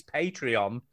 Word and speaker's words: Patreon: Is Patron. – What? – Patron is... – No, you pronounce that wrong, Duke. It's Patreon.